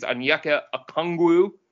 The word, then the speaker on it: Onyeka Okongwu: I don't know how to pronounce his name. I think it's Onyeka Okongwu. – Yeah, that sounds about right.